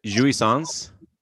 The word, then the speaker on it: jouissance: But first of all, so is it jouissance? – Yeah, I believe you said it correct.